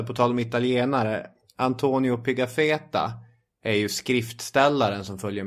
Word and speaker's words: Pigafetta: Ja, det är ju Pigafetta, så uttalar jag hela.